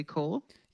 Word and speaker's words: corps: Is it spelled corp, but we say corps? – Yeah.